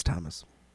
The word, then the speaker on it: Tammas: It's spelled T-A-M-M-A-S, but it's Thomas.